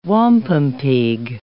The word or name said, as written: wampumpeag